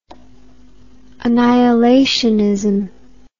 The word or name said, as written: annihilationism